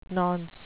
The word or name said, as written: nonce